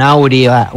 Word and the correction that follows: aura: un aurea, aura, no sé cómo se dice, aura. – Un aura que acompaña.